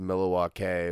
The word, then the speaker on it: Milwaukee: If I remember right, it was like Milwaukee is actually pronounced Milwaukee, which is Algonquin for the good land.